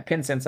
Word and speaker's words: Pinsense: But yeah, Pinsense, like incense with a P.